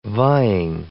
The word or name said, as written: vying